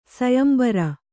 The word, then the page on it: ಸೊಯಂಬರ: soyambara